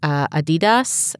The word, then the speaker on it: Adidas: And then you get a little further down where multinational brands, sports brands like Adidas and things like that.